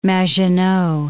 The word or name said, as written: Maginot